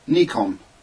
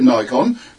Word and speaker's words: Nikon: There was an American chap doing the correct pronunciation of Nikon. So I'm still going to call it Nikon, but it's correctly Nikon.